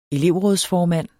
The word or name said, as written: elevrådsformand